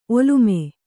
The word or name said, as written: ಒಲ್ಲಮೆ